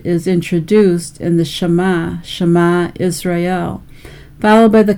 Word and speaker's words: Shema: It is Strong's number 8085, and again it's pronounced Shema.